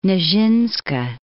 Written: Nijinska

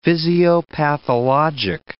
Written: physiopathologic

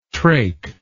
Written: traik

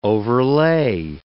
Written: overlay

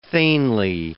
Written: thegnly

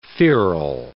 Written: feral

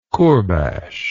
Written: kurbash

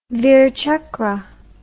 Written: virchakra

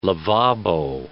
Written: lavabo

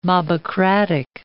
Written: mobocratic

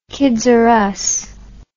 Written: kids r us

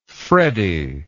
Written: freddie

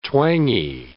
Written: twangy

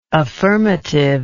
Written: affirmatively

